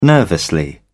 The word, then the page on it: nervously: British English